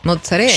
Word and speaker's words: mozzarella: Mozzarella. – Sí, a ver. – Mozzarella. En el barrio es más con ese.